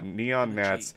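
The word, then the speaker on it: Neon Gnats: Also, just throwing this out there, because earlier you spelled your name like it's spelled in real life, but Neon Nats, Nats is spelled like the bug. – Yeah, Neon Nats, NEONGNATS.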